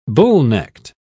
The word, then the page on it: bullnecked: British English